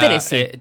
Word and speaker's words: Tennessee: Sí, mañana 30 de septiembre en Tennessee, sería Tennessee.